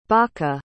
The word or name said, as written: baka